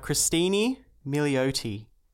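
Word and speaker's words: Cristin Milioti: Christine Milioti. – Okay. – I believe is how you say it.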